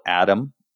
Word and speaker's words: Adam: My name is spelled Adam, A-D-A-M. Last name is Coelho, C-O-E-L-H-O.